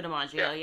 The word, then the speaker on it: DiMaggio: Joe DiMaggio, yeah.